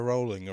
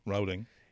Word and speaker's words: Rowling: Is it Rowling or Rowling? – Well, Rowling, I would say. Or Rowling. W. Rowling. – In Ireland, there's a sport called road bowling, but it's spelled like bowling. – Yeah. – But some people call it road bowling. – I assume it's Rowling.